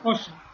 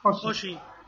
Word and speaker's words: Oggi: ¿Se pronuncia así como en portugués? – Oggi. – Oggi. Oggi, que es hoy, ¿no? – Sí.